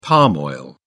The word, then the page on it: palm oil: British English